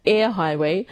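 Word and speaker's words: Eyre Highway: After I pronounced it the first time, I thought, that doesn't sound right, so I Googled it, and it's Air Highway.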